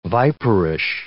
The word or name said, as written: viperish